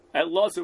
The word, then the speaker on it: Eliezer: Again, make sure we say it right, Eliezer without a yud, because we're talking about a statement of an Amora in Masechta Shabbos, Daf Tzadi Dalid Omid Beis.